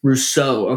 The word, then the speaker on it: Rousseau: Rousseau.